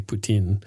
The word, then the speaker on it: poutine: gave us a 5-star review saying, I love this podcast so far and made fun of our pronunciation of poutine. And I know it's actually poutine, but I want to avoid being accused of being a Russian sympathizer because it sounds an awful lot like Putin. Putin.